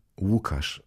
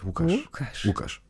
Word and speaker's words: Łukasz: Wie wow. Łukasz ist eigentlich mein Name. Das ist auch das SZ am Ende. Das L, wie L-U-K-A-S-Z. Łukasz.